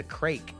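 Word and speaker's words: craic: I think it's the Irish that says that say what's the crake. It's like craik.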